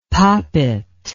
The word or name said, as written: poppit